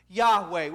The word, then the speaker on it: Yahweh: Sometimes we pronounce it, the best thing that we can kind of do is say we think it's pronounced Yahweh. We're pretty certain of it.